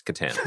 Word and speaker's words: Katan: It's pronounced like the co-star of The Night at the Roxbury. It's Catan.